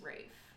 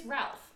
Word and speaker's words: Rafe: Oh, I guess I thought that it was Rafe. And I've seen, like, things where it's like, did you know that you're mispronouncing? But a lot of people also think it's Ralph. I know it's right. – not Ralph. – Right. It is not Ralph.